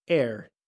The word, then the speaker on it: heir: Siguiente palabra: heir. Heir. ¿Qué significa heredero? Debemos tener cuidado, porque heir, A-I-R, significa también aire. Se escriben diferente, pero suenan igual.